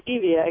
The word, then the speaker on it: stevia: Well, I'm glad you told me how to pronounce it, and I will never say stevia again. It's stevia.